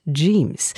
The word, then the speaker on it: Yeemz: Oh, hier kommt jetzt eine spannende Künstlerin, Jeems. Glaube ich, ausgesprochen. Y-E-E-M-Z. Jeems.